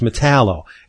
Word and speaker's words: Metallo: but a lot of people don't pronounce 00, they pronounce zero as O. So when you say it that way, metal O, so it's Metallo, and John Corbin was the original Metallo.